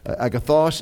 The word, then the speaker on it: agathos: The accent is on the second syllable or the third syllable, the final syllable there. Agathos and kalos.